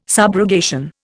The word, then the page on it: subrogation: Indian